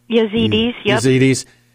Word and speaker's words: Yazidis: Am I saying that correctly? – Yazidis.